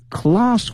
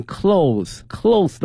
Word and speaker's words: cloth: In addition to avoiding plastic bag 塑料袋 plastic bag in favor of cloth one，这里面讲到这个布袋哈，这个布袋我们用这个 cloth C-L-O-T-H，那么这一次大家千万不能念成 clothes 的话嘛，表示衣服的什么什么东西了。